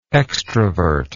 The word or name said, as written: extrovertish